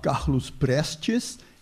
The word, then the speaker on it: Carlos Prestes: Ich werde zunächst kurz die komplizierten Leben von Olga Benario und ihrem Lebenspartner Carlos Prestes, auf Portugiesisch sagt man Carlos Prestes, ich werde es aber möglichst deutsch aussprechen.